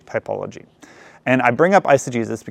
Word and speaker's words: eisegesis: That's spelled I-S-O. How do you spell G-G. It's not I see Jesus, which is a great way to remember it, but it's eisegesis.